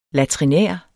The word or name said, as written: latrinær